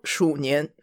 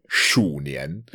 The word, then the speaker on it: 鼠年: It's called 鼠年. – 鼠年.